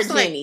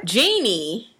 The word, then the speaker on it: Jeannie: Why you call her Jenny? Like, you know that is not her name. – Oh. What's her name? What? – Jeannie.